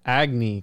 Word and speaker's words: Agni: I think it's said Agni, because it's spelled A-G-N-I.